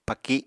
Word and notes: paki: Apatani

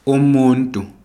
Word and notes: umuntu: ndebele

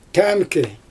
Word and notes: tæmkɪj: Xyzyl

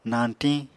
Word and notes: nañtiñ: Apatani

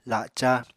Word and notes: laca: Apatani

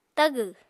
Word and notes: tagw: Apatani

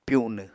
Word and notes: pyunw: Apatani

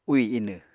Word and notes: úì inw: Apatani

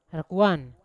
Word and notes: rakuan: Yawa

